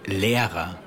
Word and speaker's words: Lehrer: L-E-R-E-R. – Where's the silent H? – You didn't hear me say it because it was silent. Okay, that wasn't funny. Here's the correct spelling for teacher. L-E-H-R-E-R. – Good. – So nehmen, N-E-H-M-E-N, meaning to take, has the silent H.